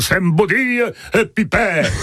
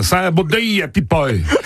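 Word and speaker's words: Saint-Baudille-et-Pipet: Oui, ben non, ça se prononce pas Saint-Baudille et Pipet. Mais ça se prononce bien Saint-Baudille et Pipet. – Il y a une vraie différence.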